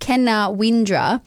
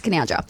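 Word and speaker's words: Caloundra: It looks like it should be pronounced Kenawindra or Kenawindara or something fancy, but no, it's Canoundra.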